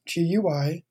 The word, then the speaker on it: GUI: GUI stands for Graphical User Interface, GUI. Uh, most people pronounce it GUI, like a GUI chocolate chip cookie, for example.